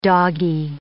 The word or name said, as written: doggy